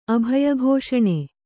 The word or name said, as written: ಅಭಯಘೋಷಣೆ